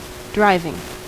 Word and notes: moteur: /mɔ.tœʁ/; [mɔ.tœʁ]

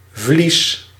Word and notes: fleece: American English; [flis]

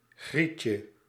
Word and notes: tyttö: /ˈtytːø/

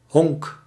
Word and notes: bas: /bɑːs/

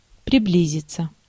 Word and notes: приблизиться: /prʲɪˈblʲizʲɪt͡sə/

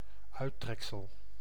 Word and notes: epitome: /ɪˈpɪt.ə.mi/; /iˈpɪtəmi/

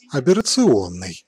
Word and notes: aberrant: [a.be.ʁɑ̃]; /a.bɛ.ʁɑ̃/

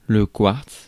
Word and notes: quartz: /kwaʁts/

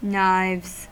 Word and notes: knives: /naɪvz/

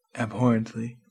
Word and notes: abhorrently: /æbˈhɔɹ.ənt.li/; American English

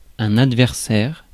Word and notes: adversaire: France; /ad.vɛʁ.sɛʁ/

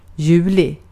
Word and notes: heinäkuu: /ˈhei.næˌkuː/

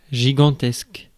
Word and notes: gigantesque: [ʒi.gɑ̃.tɛsk]; France

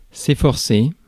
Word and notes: efforcer: [e.fɔʁ.se]; France

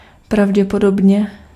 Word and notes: pravděpodobně: /pravɟɛpɔdɔbɲɛ/

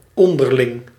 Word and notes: reciprocal: /rɪˈsɪ.prək.(ə)l/

